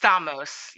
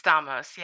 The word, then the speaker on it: Stamos: It's Stamos. Yep, it's Stamos, yeah.